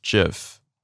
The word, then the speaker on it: GIF: 而且那个 GIF 档真的好传神哦！我有的时候我好喜欢，现在好喜欢在 Instagram 跟大家聊天，然后我都会就是找那个很传神的，就是 GIF 动图，嗯、然后有的时候是出自于电影什么的，我觉得它就可以胜过一千个字。嗯，这样对啊。 – It's pronounced GIF。 – GIF 吗？GIF 不是 GIF 档哦。